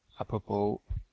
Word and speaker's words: apropos: One interesting utility for dealing with main pages is the apropos common, apropos, which is spelled A-P-R-O-P-O-S, apropos in French, will search the main pages in the name of the command, but also on their description.